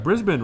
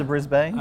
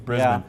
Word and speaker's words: Brisbane: Brisbane, right? – That's it. – Did I say it right? – You did. As opposed to Brisbane. – I know. It's not Brisbane, people. It's Brisbane. Yeah.